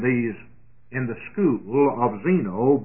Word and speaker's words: Zeno: The word Zeno is spelled as it's pronounced, Z-E-N-O.